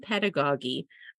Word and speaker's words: pedagogy: Just little known fact, Casey just and I just looked up the pronunciation of that word because we've said the word like three different ways, and Google told us that it's pedagogy.